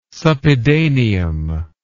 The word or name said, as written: suppedaneum